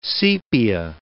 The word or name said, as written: sepia